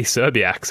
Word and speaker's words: Szczerbiak: Wally Szczerbiak.